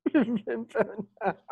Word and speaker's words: Wim Wenders: How do you say his name? – Wim Wenders? Wim Wenders. It's German. Yeah, he's German.